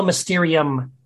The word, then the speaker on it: Mysterium: Sola Mysterion. Is that how you pronounce it? – Sola Mysterium. Yes. – Mysterium. Thank you.